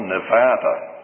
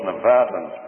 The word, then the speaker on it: Nevada: I heard someone the other day keep saying over the radio, Nevada. Or maybe those Nevadans pronounce it that way, but it's really Nevada.